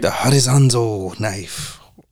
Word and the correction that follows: Hattori Hanzo: Hattori Hanzo.